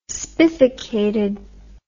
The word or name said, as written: spificated